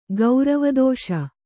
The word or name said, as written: ಗೌರವದೋಷ